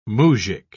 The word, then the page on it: muzjik: British English